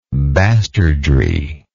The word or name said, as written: bastardry